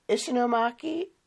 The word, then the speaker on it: Ishinomaki: And I don't want to butcher the name, is Ishinomaki? – Yes, you said it good.